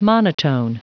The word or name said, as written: monotone